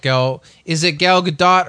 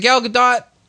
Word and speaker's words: Gadot: So she says it, it's Gadot. But every podcast I've listened to since then, like other people keep saying Gadot.